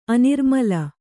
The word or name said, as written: ಅನಿರ್ಮಲ